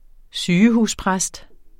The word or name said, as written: sygehuspræst